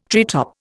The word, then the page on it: treetop: Indian